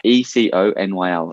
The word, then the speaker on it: Econyl: There's a really cool fabric called Econil. I can never pronounce properly. It's Econyl. I've heard about 8 different pronunciations for it. Some say Econyl, some say Econyl. You can take your pick.